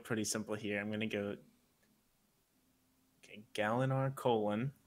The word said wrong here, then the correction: Gavilar: Wait, did I say it wrong? – Yep, Elliot, give him the pronunciation, or give him how you would say it again. – Okay. – Gavilar.